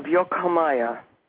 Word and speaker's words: Yokomaya: It's Yok, Y-O-K, with a thing, H-A-H-M-A-Y-A. It's the Cosmic Initiatic University of Yokomaya, Merida, Yucatan, Mexico.